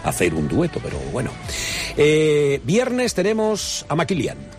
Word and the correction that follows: Malikian: Ala Malikian.